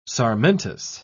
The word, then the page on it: sarmentous: British English